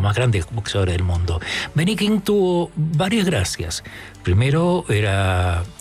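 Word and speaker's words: Ben E. King: Hoy habría estado de cumpleaños Benny King.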